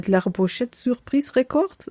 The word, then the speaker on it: La Pochette Surprise: Ich würde mal sagen, man spricht es komplett französisch aus. Wilde la Pochette Surprise Records, sagt man so? – Wunderbar, ja, genau.